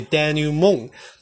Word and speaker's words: denouement: denouement，denouement，他念 d e n o u e，denou，d e n o u e，denouement，因为 m e n t，m e n t，denouement，它这个 t 是不发音的，所以 denouement，它就是指一般小说戏剧的收场，或是任何复杂情况的一种解套或解决方法。